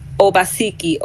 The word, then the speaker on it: Obaseki: Jaye is spelled J-A-Y-E. And my last name is M-C, capital N-A-I-R, Obaseki, O-B-A-S-E-K-I.